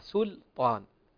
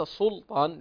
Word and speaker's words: سلطان: ننبه أخواني، كلمة سلطان هي مستفتحة بالسين المضمومة وبعدها حرف اللام، فالأصل أن تنطق مرققة سلطان وليس سلطان، لأن الذي يجعل السين صادا سيفخم اللام.